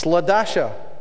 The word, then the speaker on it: Ladasha: And now you go to school, and I did hear a story of a kid whose name was spelled L-A-A. And the teacher pronounced it La, and she said, it's not La, it's Ladasha.